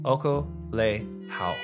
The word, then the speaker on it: okolehao: And then pick up some of the, it's spelled O-K-O-L-E-H-A-O, Okolehau.